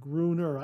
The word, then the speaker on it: Gruner: Like, I reckon the second syllable should be a schwa sound. The ner part is given less emphasis and is to be usually less clear. Gruner.